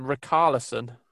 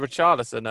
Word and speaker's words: Richarlison: You're a stadium announcer, and you called him Rickarlison when referring to Richarlison earlier.